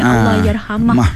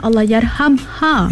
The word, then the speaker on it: Allahyarhamha: Ramai yang menggunakan salah perkataan ini. Allahyarhamah. Tapi sepatutnya Allahyarhamha.